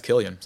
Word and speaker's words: Cillian: It's Cillian.